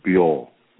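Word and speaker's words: Byo (猫): and cat in the Japanese Chinese character pronunciation is Byo, B-Y-O.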